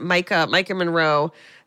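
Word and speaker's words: Maika Monroe: Is it Micah Monroe, that she was from It Follows? – Yes.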